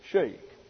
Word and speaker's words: Schoeck: S-C-H-O-E-C-K. And I've quoted from this man before, and I asked Katie how to pronounce his surname, and she said Schenk.